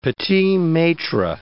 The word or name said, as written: petits-maitres